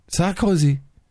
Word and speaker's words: Sarkozy: That's how you got to pronounce it. Sarkozy.